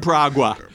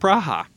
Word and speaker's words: Praha: We're a big deal in Pragua. – Pragua. – Which is properly pronounced Praha.